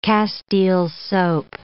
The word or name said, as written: castile soap